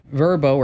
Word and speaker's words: Vrbo: Now, Vrbo or VRBO, as you want to pronounce it, they say no indoor cameras at all.